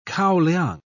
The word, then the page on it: kaoliang: British English